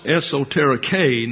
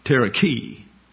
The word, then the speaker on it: esoterike: It's a long E in Greek. And that's why the line is over the top. An epsilon, like this, is an E sound. And an eta is a long A, as if it were a long A in English. So it is pronounced esoterike, not terike.